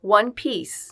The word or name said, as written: one piece